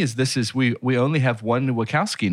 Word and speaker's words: Wachowski: One of the things that stood out to me is that we've got the Wachowski. Am I pronouncing that right? – I think it's Wachowski, huh? – Wachowski, you're right.